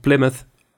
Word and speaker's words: Plymouth: kunnen we nog één keer zeggen? Plymouth. – Plymouth? Plymouth. Plymouth. Oké. – Ja, of ik weet niet hoe je het, Hoe spreek je dit uit? Ja, volgens mij zeg je het fantastisch goed.